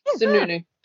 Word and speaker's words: Sununu: I actually looked up how to say his name, and that's how you say it. Sununu.